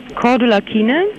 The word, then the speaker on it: Cordula Kine: Oh, wie schreibt man das? Cordula jetzt hier? – Mit C. – Zeppelin oder was? – Nee, C wie Cäsar. – Ja, weiter. – C-O-R. – Rudolf, dann weiter. Dora. – U-L-A. – U-L-A. Mhm. Hab ich. Mein Name ist Cordula. Weiter. – K-I-E-N-E.